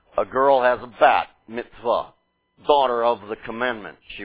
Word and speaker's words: mitzvah: Of the mitzvah in modern Hebrew or Ashkenazi pronunciation, mitzvah, is how I'd pronounce it.